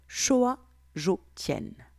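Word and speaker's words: Xiao Zhou Tian: En chinois, l'orbite microcosmique s'appelle Xiu Zhu Tian et se prononce Shoa Zhou Tian.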